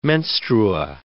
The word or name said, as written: menstrua